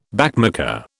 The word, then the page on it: backmarker: British English